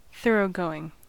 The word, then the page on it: thoroughgoing: American English; [ˈθɜɹ.oʊ.ˌgoʊ.ɪŋ]